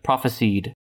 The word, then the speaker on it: prophesied: Well, it doesn't say it. There's no Z here. Prophesied.